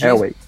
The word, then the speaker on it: Elway: Uh, até porque o head coach dos Broncos, é, segundo o general manager, né, o John Elway, é, diz... Elway.